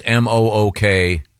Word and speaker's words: mukbang: It's actually pronounced muk. Yeah. – Is it muk? – Mukbang. – Yep. Oh, I thought it was mukbang. – The pronouncer here is mook.